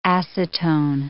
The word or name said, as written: acetone